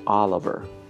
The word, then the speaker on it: Oliver: Oliver Oliver Olive,